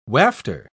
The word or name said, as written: wafter